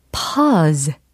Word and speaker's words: pause: p-a-u-s-e 라는 철자입니다. 뭘까요? 그래요. pause. 이렇게 길게 끌어주셔야 하는 발음입니다.